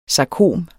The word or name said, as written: sarkom